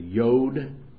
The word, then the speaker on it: Yod: It says J-O-D, but the J in Hebrew is pronounced like you would yes. So it's the Yod.